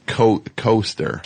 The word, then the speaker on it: Koester: Megan Koester. And it's Koester. – K-O-E-S-T-E-R. – I'm just for the people typing. – Right. – Koester. That's how you spell it.